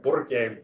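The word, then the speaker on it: Burque: You got to roll your R. Burke Worldwide. I'm from Albuquerque. Burke Worldwide.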